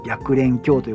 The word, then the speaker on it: 白蓮教: で、その新興宗教の中で最大のものは白蓮教白い蓮の教えと書いて白蓮教と読むんですが、えー、白蓮教が流行っておりました。まあ、ここはまたね、前回も言いましたね。で、この白蓮教